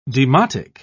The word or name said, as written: demotic